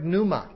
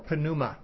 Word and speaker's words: pneuma: The word pneuma or pneuma has a silent P at the beginning.